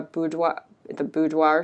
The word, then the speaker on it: boudoir: Would you recommend like a doing, you know, like a pinup shoot or a boudoir shoot? I'm saying that right. – Absolutely. Yeah, totally.